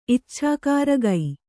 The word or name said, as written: ಇಚ್ಛಾಕಾರಗೈ